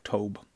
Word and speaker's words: Tobe: Two subjects that Toby, would it be Tobe or Tob? Tob? Tobe? I'm not too sure. Claudium is trying to help me with my pronunciation, and it's my French reading that makes it hard for me sometimes to globe, Tobe. Rhyme with globe.